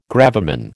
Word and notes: gravamen: American English

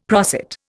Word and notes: prosit: Indian

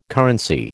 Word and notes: currency: American English

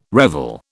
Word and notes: revel: British English

